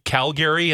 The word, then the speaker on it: Calgary: Did your dad move buildings to Calgary and that's why he called it that? – No, that's just the correct pronunciation, Calgary. – It's like Calgary, take me away. It doesn't sound right.